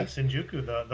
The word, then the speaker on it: Shinjuku: Is that pronounced? – Yeah, Shinjuku.